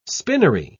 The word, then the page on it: spinnery: British English